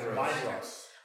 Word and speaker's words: Maedhros: Maedhros.